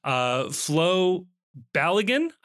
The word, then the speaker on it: Balogun: Flo Balogun, I believe is how we've decided to pronounce this name, has committed to the United States.